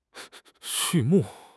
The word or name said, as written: 畜牧